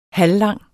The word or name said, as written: halvlang